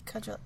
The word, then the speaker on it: Kajol: at the time of this filming, the actress, was it Kajol? Actress? – Kajol. – Kajol, yeah. She, that's right, Kajol, like the weapon spelled with a C.